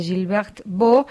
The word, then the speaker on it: Gilberte Beaux: A partir de hoy, entiendo que en todas las librerías, porque se está lanzando Una mujer libre de Gilbert Beau. Se escribe Gilberte Beaux.